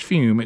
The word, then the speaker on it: Fum: Go to TryFume, that's T-R-Y, then F-U-M. All right, even though it's pronounced Fume, it's spelled F-U-M.